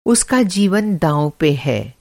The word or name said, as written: दाँव